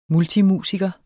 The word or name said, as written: multimusiker